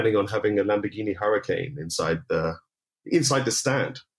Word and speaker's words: Huracan: Oh, it's it's spelled H U R A C A N. So that's uh, right. – Yeah. All right, the Huracan. Got it.